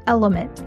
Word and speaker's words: LMNT: This podcast is brought to you by Element, that's spelled L-M-N-T, which is a company that I have the chance to invest in and have loved since day one.